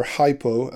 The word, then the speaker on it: hypo: The prefix hypo or hypo, as it would be said, uh, pronounced in English, means under, and stasis can be translated as to stand.